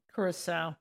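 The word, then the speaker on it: Curaçao: So we got schnapps, Maduro, Bailey's, blue caracha? – Curacao. – Curacao, sorry.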